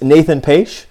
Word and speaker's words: Nathan Paetsch: Our guest today is Nathan Paetsch.